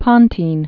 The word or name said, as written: Pontine Marshes